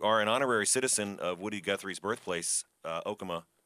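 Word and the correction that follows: Okemah: Yeah, and it's pronounced Okima. You don't want to make that mistake in town. – I will never, ever again say Okama. It's Okima.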